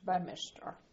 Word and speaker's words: Beaminster: The summer school had taken place in a very beautiful part of Dorset, called Ewan Minster, near the little town of Beaminster, which is pronounced Bemister.